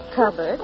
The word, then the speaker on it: cupboard: Hubbard went to the cupboard. – It rhymes with Hubbard.